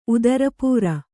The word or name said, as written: ಉದರಪೂರ